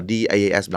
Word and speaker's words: DAS: tài sản số và S là service mình đọc là DAS